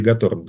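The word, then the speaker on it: Готорн: Кто-то Гаторн говорит, кто-то Готтерн. Я не знаю, как правильно. – Натаниэль Гаторн, да.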